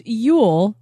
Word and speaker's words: Ewell: General Yule is pronounced Yule. Denise, you were right. – Yes, Denise was right. – So when I said, I think it's Ewell, I was wrong.